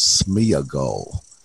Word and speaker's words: Sméagol: And then there was Sméagol. That's the pronunciation I came up with. – Which is correct. – Yeah, Sméagol, Sméagol.